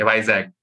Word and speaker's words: Vizag: Vizag. Okay. I was pronouncing it incorrectly. Okay. Vizag.